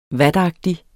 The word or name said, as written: vatagtig